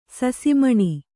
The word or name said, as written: ಸೂಸುಮಣ್ಣು